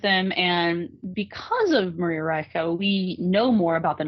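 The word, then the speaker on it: Reiche: And they, I believe there was a woman named Maria Ricci. Well, I know there was a woman named Maria Ricci. And I think the pronunciation, she was German, so it's Maria Reicha.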